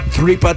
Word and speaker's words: tripa: Number three was tripa tacos. I believe I'm saying that right. – Yes. – Tripa tacos.